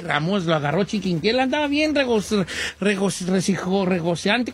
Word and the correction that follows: rozagante: ¿cómo se dice? Rezagante él. – Rozagante. – Rozagante él, cuando estaba muy ahí, muy machito, que lo agarra chiquinquiera y bolas.